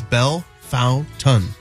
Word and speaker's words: Bellefontaine: I looked up Bellefontaine. Bellefontaine. Assuming it would be the French correct pronunciation. – And it's the same. – And it's Bellefontaine.